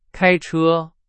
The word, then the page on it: 开车: Chinese